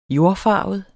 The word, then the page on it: jordfarvet: [ -ˌfɑˀvəð ]; [ -ˌfɑːvəð ]